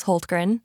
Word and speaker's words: Hultgren: Well, my last name is Hultgren. It's very Swedish.